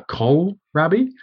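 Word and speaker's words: kohlrabi: It's spelled K-O-H-L, rabi. So a col rabi.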